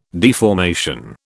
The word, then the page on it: deformation: British English